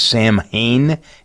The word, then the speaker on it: Samhain: Uh, the Celts are the ones that really kind of they had a festival called I believe it's pronounced Samhain, S A M H A I N, recognizing the end of the harvest season.